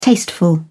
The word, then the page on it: tasteful: British English